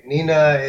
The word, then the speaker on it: Hnina: It's Hnina Gourmet. Hnina is H-N-I-N-A. H is silent for most nationalities. Gourmet, G-O-U-R-M-E-T.